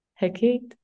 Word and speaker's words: Hecate: And she was often mentioned as a priestess of the goddess Hecate, who, or I don't know how you pronounce this in English. I just Hecate? Say it with Hecate? My Spanish accent. Hecate, I'm sorry. Hecate? – Yeah.